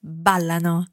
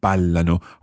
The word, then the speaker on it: ballano: Okay, Again, remembering that stress on the end of ballano, or indeed not on the end, but on the end of the verb conjugation, because we've got a different stress on ballano in comparison to all the other parts of that verb.